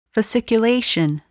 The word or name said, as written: fasciculation